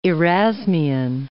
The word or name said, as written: Erasmian